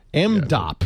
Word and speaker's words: MDOP: MDOP. Yeah. Is that how you say it? – Yep.